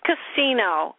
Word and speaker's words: Casino: Denise Casino. C-A-S-I-N-O. – So just like a gambling place.